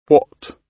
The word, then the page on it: what: British English